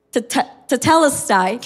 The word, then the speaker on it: tetelestai: It is pronounced as tetelestai.